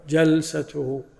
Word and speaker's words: الجلسة: فإذا أردنا الجلوس يعني الجلسة الواحدة المرة ننطق بالفتح جلسته، وإذا أردنا الصفة نذكر بالكسر.